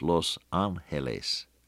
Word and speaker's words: Los Angeles: Well, if you give it the right Spanish pronunciation, it's Los Angeles.